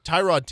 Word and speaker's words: Tyrod: And he came out and said, yeah, my name is actually pronounced Tyrod Taylor. – Mm. – And most people just have kind of blown by that and just been like, yeah, we've been calling you Tyrod for so long.